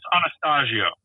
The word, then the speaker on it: Anastasio: It's Anastasio.